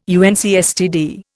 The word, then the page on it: UNCSTD: Indian